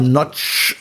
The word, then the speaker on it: Nagy: Geschrieben Nagy, falls irgendjemand. – Nagy, aber Nagy ausgesprochen. Also Nagy ist richtig.